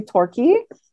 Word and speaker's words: Torquay: That's it's located in, uh, I hope I'm saying this correctly, Torquay, Australia. – That's, ex- Perfect.